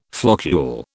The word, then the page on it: floccule: British English